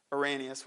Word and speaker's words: Irenaeus: The early church father, Irenaeus, which is the American way of pronouncing that, argued, so this is one of the early church fathers.